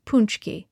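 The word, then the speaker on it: pączki: Uncle says to pronounce it punchki, a jelly-filled donut.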